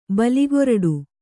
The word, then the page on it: ಬಾಲಕ್ರೀಡೆ: bāla krīḍe